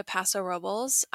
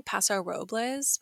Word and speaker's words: Paso Robles: If any of you ever go to Paso Robles, I highly recommend. And by the way, I believe it's pronounced Paso Robles. I've been saying Paso Robles before I ever went there, but then everyone there said Paso Robles.